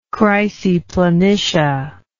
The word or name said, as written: chryseplanitia